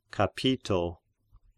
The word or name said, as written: Capito